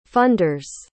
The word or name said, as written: funders